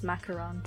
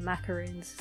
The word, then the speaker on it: macaron: I know it's pronounced macaron, but I'll still call them macaroons.